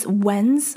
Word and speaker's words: when's: when's when is when's 最后的 pure when's w h e n p r s when's it's a z sound when's when's z when's When's a good time?